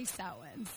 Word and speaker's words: Samhain: And there's a lot of different pronunciations of that, so in talking with people, everyone kind of has their own twist on it, but I say Samhain, so that's where we're at today.